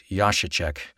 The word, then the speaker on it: Jacaszek: The album is called Music for Film from the composer Jacek, and that's spelled Jacasek, pronounced Jacek.